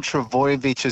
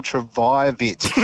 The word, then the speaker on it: Trbojevic: Mate, I love you, but you mispronounced Tom Trbojevic as Tom Trbojevic. You went for a vi instead of a voy.